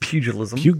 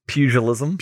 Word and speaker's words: pugilism: Testing the, forgive me, I don't know how to pronounce it. – Pugilism. – Pugilism.